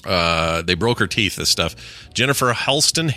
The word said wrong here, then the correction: Haston: Haston, I think is how you say it. Haston. – Yep.